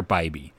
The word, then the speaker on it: Bibee: Tanner Bibby, right? No, or is it Tanner Bibee. I've been saying it wrong.